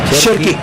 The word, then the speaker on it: Cherki: Cherki.